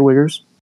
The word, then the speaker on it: Uyghurs: That's the pronunciation. It's all right. You can say Uyghurs.